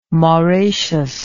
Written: moraceous

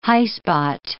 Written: highspot

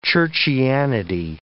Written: churchianity

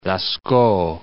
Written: Lascaux

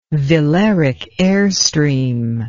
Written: velaric airstream

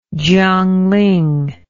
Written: Jiangling

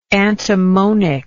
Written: antimonic

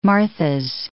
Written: Martha's Vineyard

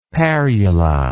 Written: parula

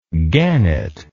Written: gannett

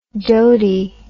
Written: dotey

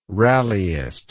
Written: rallyist

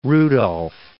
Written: Rudolf

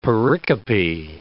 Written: pericope

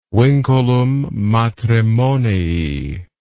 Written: vinculum matrimonii

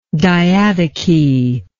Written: diadochic

